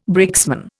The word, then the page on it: brakesman: Indian